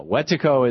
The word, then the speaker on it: Wetiko: I believe I'm saying it right. Wetiko capitalism? – Yeah, Wetiko is the word that Jack Forbes, the professor of Native American Studies, now deceased at UC Davis, gave me.